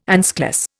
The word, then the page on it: anschluss: Indian